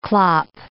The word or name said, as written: clop